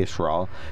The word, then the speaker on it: Yisrael: Her husband, Rabbi Yisrael. Okay. I just love that pronunciation. And is spelled with a Y? – Yeah. It's the Hebrew word of Israel. – All right. Fantastic. Mm-hmm. Yisrael Baumgarten is the rabbi for Chabad of Lewisburg, which is a facility.